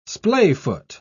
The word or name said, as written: splayfoot